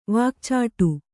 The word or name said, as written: ವಾಕ್ಚಾಟು